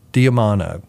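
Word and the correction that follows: D'Adamo: No, I didn't say that right. J. D. Adamo. It's D apostrophe A-D-A-M-O.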